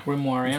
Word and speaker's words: Grimoire: Siis mängisin free to play Grimoire või kuidas hääldas seda Manastorm. – Grimoire, jah.